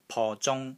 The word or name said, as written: PO3 ZUNG1